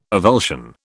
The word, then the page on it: avulsion: British English